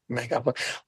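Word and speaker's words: Megalopolis: So, I mean, I haven't seen Megapolis. Is that how you say it? – Yes. – Megapolis.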